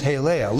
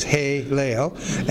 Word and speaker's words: Helel: Looks like this in the Hebrew, H-E-L-E-L. Here's the H. Here's the little E, those three dots. There's the L, and here's another E, and then another L. Haleel. Pronounced like A's, Haleel.